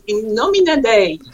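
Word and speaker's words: In Nomine Dei: Did I pronounce that right? – Almost right. Yes. – Almost. Okay. – That was gracious. – That's a universal language out there. So how do I pronounce that without sounding like a Southerner in the United States? – From Latin, so you shall pronounce it in Nomina Dei.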